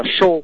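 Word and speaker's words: Shō: tiểu đọc là Sho, nó viết tiếng Anh là S H ô mà nó có cái dấu ngang dài ở trên đầu, mà không mình bỏ dấu ngang mình đánh mạng cũng tìm thấy được dễ dàng.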